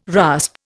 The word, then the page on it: rasp: Indian